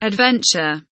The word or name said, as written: adventure